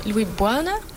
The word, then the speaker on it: Louis Buana: En nu zie ik een brief van Louis Buana. Zeg ik dat goed? – Ja, dat zeg je goed.